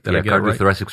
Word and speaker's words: cardiothoracic: Cardiothoracic surgeon.